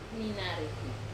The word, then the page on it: nanaripi: Wamesa